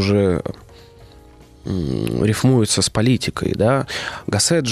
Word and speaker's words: Гассет: Гассет, да, я туда-сюда прыгаю, ударение.